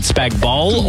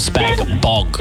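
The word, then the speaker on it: spag bol: Is it Spag Bowl or Spag Bog? – Spag Bowl, the G is silent.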